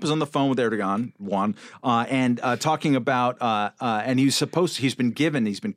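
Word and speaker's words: Erdoğan: Erdogan. – It's got a G. Like, it's a fucking G. – Soft G. – I've heard both. – Very soft G.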